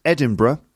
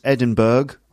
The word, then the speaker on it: Edinburgh: So the Edinburgh Festival, and yes, it's pronounced Edinburgh, not Edinburgh or Edinburgh, Edinburgh.